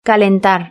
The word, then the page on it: calentar: Spain Spanish